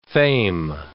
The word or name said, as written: fame